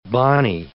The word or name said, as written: bani